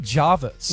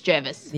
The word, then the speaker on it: Jervis: So it is spelled Jervis, but people from Sydney call it Jarvis. – Yeah. – So where are you going? – Jarvis Bay. – Jarvis Bay. – Yeah, but on that, it also says the local pronunciation is Jervis.